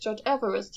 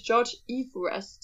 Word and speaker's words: Everest: Aber was jetzt interessant zu wissen ist, ist, dass George Everest eigentlich gar nicht George Everest hieß, weil das nämlich falsch ausgesprochen ist. Er hieß eigentlich George Everest.